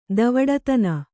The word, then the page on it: ಧವಡತನ: dhavḍatana